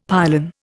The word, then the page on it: pylon: Indian